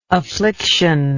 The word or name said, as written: afflictionless